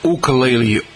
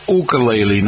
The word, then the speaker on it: ukulele: And usually when you go see the Cape Cod Ukulele Orchestra, first of all, make sure you say it correctly, folks. It's ukulele, not ukulele.